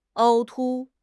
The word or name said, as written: 凹凸